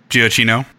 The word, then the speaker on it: Giacchino: But considering Michael, you know, was it Michael Giacchino? – Giacchino.